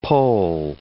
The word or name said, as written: poll